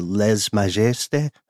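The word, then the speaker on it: lèse-majesté: Their government is extremely different from the West, especially in their practice of, help me with the French pronunciation here, the lèse majesté or lèse majesté. The rights of the king. – Guessing for a silent S there, but that's my only critique.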